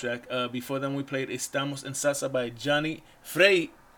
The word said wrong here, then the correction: Freire: Let me say it right. Freire.